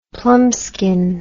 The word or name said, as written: plumskin